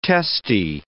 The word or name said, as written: testae